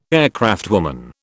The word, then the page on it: aircraftwoman: British English